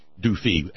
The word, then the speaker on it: DoFi: DoFi, actually. We pronounce the I as E, as most European languages do, not I.